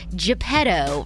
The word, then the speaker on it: Geppetto: And Tom Hanks is in early talks to play the fatherly figure Geppetto, right? That's how you say his name, in Disney's live-action adaptation of its classic animated tale Pinocchio.